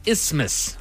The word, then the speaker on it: Isthmus: The correct way to say it is Isthmus.